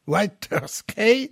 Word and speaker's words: Waterschei: Il est fils de mineur donc en Belgique à Waterschei. Excuse-moi de la prononciation. – Waterschei.